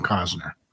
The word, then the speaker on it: Costner: You know, there's a T in there, but it's like, if you're going to say the name, it's like Kevin Costner. It's almost like you have to like not pronounce and enunciate that T.